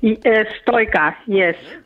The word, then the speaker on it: Stoica: And you do pronounce it Stoica? – Stoica, yes.